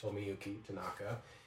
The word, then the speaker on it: Tomoyuki Tanaka: I apologize to those if I'm pronouncing that incorrectly, but I believe it is pronounced Tomoyuki Tanaka.